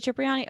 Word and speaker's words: Cipriani: Then he met Jenny Cipriani, in Italian it's Cipriani. – Yeah, what? Jay-Z, Cipriani's basement. – Oh, they do pronounce it Cipriani? Okay, good to know. Yeah. So it is Cipriani.